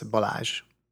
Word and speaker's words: Balazs: Balat Actually, can you can you say your name correctly for me? – Uh, you're you're quite uh, doing quite well. It's Balazs.